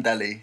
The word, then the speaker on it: Dally: It's Ben, it's either Daly or Dally. – Dally, it's Ben Dally. – You reckon it's Ben Dally? – It is, it's D-A-L-L-Y, which is no I, therefore pronounced Dally.